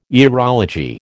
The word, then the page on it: aerology: American English